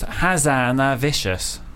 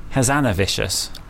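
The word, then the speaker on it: Hazanavicius: It actually is pronounced Hazanavicious. Hazanavicious.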